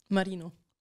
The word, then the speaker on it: Marino: Marino. – Marino. You got to say it like that? You can't just say Marino. – Giuliana Marino. You got to put that little tongue thing on it.